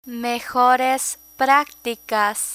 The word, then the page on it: mejores practicas: Spanish